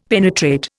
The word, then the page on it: penetrate: Indian